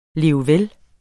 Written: levvel

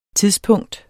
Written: tidspunkt

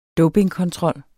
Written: dopingkontrol

